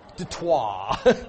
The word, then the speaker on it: Detroit: O-I-T. That's not wah. Detroit. That's French.